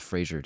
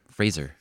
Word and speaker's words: Fraser: Everybody says Fraser, dude. – Well, how do you pronounce it? – Correct it. Like Razor. – Oh, yeah, that's right. – Brendan Fraser. Fraser.